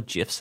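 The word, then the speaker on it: GIF: Or GIFs, how you pronounce it? – Like graphs. – Like, graphs. Cool graphs.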